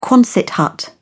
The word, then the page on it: quonset hut: British English